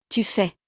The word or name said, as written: tu fais